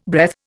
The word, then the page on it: breath: Indian